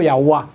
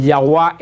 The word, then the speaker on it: Yahweh: Entonces llegaron a la conclusión que se debió, pero es una suposición, haber pronunciado Yahweh. Dijeron que podía haber pronunciado, y dice que a lo mejor podía ser pronunciado el Yahweh, ese vino Yahweh, y después por una corrupción de la Y con la J, como en el siglo XI surgió la palabra Jehová, que es una corrupción de una traducción de una suposición.